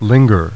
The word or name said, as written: linger